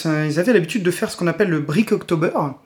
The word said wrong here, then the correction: Bricktober: Euh, non, pardon, le Bricktober, il faut dire.